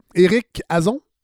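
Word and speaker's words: Éric Hazan: et Éric Eric Azon. Oui, exact. J'ai prononcé comme il faut? – tout à fait comme il faut.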